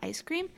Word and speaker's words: ISCREAM: like spelled spelled like the is cream, like I S C R E A M, but it's the ice cream.